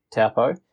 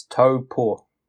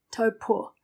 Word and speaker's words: Taupo: But it's same with Taupo. I think it's just become the norm for non-New Zealanders to pronounce it incorrectly. – Yeah. But even us down south, we'll say Taupo. But it's it's Taupo.